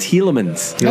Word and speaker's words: Tielemans: Tielemans.